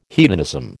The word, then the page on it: hedonism: American English